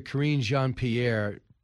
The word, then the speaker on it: Karine Jean-Pierre: But see, Charlie, first I want to let you hear Press Secretary Karine Jean-Pierre – push back.